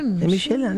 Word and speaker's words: Michelin: Michelin, Susana, ¿cómo lo pronuncia usted? Michelin, es así.